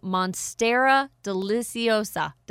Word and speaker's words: Monstera Deliciosa: Monstera Deliciosa.